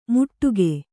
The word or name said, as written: ಮುಟ್ಟುಗೆ